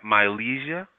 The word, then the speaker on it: Mylezia: Mylezia? – Yeah.